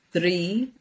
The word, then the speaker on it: draoi: So it's Ban, B-A-N, Dri, D-R-A-O-I, pronounced Dri, like D-R-E-E.